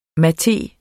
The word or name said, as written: MATEEN